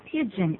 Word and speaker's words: pidgin: 那个叫做 pigeon English。 – 啊。 – pigeon English， pigeon 的拼法是 p i d g i n， pigeon。可是这又不是鸽子英语啊，鸽子的发音是一样的，拼法是 p i g e o n。